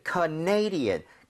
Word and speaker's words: Canadian: 왜냐면 캐나다는 Canada 이렇게 가는데 네. – 이게 캐나다 사람 이러면은 Canadian 강세가 뒤로 하나 밀려요.